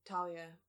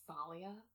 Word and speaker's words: Talia: Or actually, I should say Thalia, because it's how they say it in the movie, and I hate it. And I understand Thalia. That's how a lot of people pronounce the name.